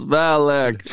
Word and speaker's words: Valak: Valak is the shit, bro. Two syllables, Valak.